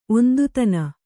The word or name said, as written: ಒಂದುತನ